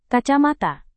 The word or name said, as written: kacamata